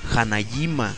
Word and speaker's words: Hanajima: Y dice que se pronuncia como, ya me regañó, me dijo, no, no, idiota, se pronuncia Hanajima. Hanajima.